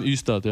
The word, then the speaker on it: Ystad: Also es wird, glaube ich, Ystad ausgesprochen, oder? – Ystad, ja.